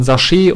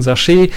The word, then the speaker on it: Sachet: Bau deine eigene Wim-Config mit Hilfe von Sachet oder Sachet, je nachdem, wie man es aussprechen möchte. Ich würde eher das Französische bevorzugen, Sachet.